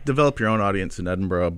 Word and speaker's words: Edinburgh: How do you say it? – Edinburgh. – Edinburgh. – Yeah, there we go.